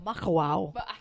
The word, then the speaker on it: Makawao: how did you say it? – It was called Makaha. – Well, Makawao. – It's Makawao. Yeah.